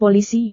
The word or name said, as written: polisi